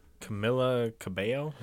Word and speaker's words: Camila Cabello: Yeah, that's okay, for one of mine that I hate, it's actually this year, Camila Cabello.